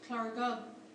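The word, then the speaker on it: Llareggub: The fictional village, Clargub, this is pronounced with a Welsh accent, in Under Milk Wood, read it backwards and it spells bugger all.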